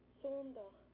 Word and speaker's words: Sondag: Sondag. – Sondag. S-O-N-D-A-G.